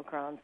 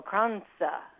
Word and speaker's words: kransa: My friend who does come from a Swedish tradition tells me that you have to bounce the S when you pronounce it. So I called it cardamom krans when I was talking to her. She said, no, it's cardamom kransa.